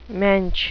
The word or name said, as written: mensch